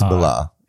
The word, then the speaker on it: Millar: Is it Millar or Miller? Because Millar is not a name. – It's Millar with an A. – It's Millar. Yeah. A-R. But is that how you say it? – Yes. – Okay.